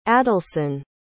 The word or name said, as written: adalson